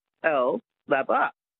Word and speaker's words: Capalaba: And at any age, just to learn how to say Kapalabar. – Oh, well, it might not be my show you're enjoying then. – Because that's how, if you look at it, it's C-A-P-A-L-B-A, Kapalabar.